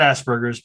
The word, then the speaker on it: Asperger: And yes, I know I presented as, big air quotes here, people, high functioning autistic or Asperger's syndrome, not Asperger's, because Hans Asperger pronounced his name as Asperger.